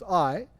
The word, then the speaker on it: Ai: You see these two letters, Ai. Some say it's Ai, some say it's, you know, it's just the most appropriate pronunciation is Ai, which is near Beth Aven, east of Bethel, and said to them, go up and spy out the land.